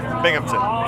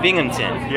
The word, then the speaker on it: Binghamton: Is that right? – Binghamton. – Bimington. How do you say it? – Binghamton. – Binghamton. – Yeah. – Oh, I spelled it wrong. How do you spell it? – B-I-N-G. – Oh. – H-A-M. – Oh. Why didn't you tell me? – Sorry, man. – Binghamton. – Yeah, Binghamton.